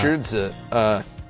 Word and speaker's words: Shih Tzu: It's pronounced Shurza, first of all.